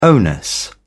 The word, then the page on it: onus: British English